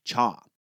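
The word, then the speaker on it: cha (茶): The people of these regions spoke the Cantonese dialect, which pronounces the word for tea as cha.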